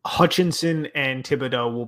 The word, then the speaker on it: Thibodeau: Neil Hutchinson or Thibodeau, did I pronounce it right that time? – Yep.